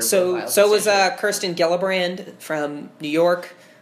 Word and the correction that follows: Gillibrand: So Gillibrand is like, to correct your pronunciation,